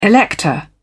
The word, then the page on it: elector: British English